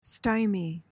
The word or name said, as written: stymie